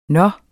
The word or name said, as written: nå